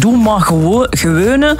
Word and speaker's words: doe maar gewone: Doe maar, nee, niet gewoon, hè. De G, dat is moeilijk voor de rest.